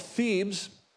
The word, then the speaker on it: Thebes: Well, Nahum notes the fall of Thebes, is how you pronounce that, but not its reconstruction.